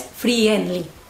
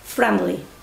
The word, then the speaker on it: friendly: Cuidado en la pronunciación, que tenéis que mandar la I a tomar por culo, ¿eh? No es friendly, es friendly, friendly, friend, friendly.